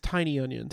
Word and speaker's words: Tynion: That's I've I said James Tynion's name wrong for years. It's pronounced tiny onion.